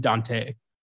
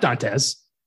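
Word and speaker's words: Dantès: My favorite thing is that the character is supposed to be pronounced Edmond Dante. – Is it really? – Yeah, it's not Dantes, but nobody told Kevin Reynolds and he just rolled with it.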